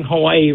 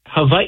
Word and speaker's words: Hawaii: You know, we're also like technically we're also pronouncing Hawaii wrong. Like Hawaii, it's Hawaii. You know, because in Hawaiian language the W's are actually, they sound like V's.